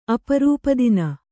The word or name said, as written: ಅಪರೂಪದಿನ